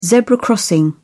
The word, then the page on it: zebra crossing: British English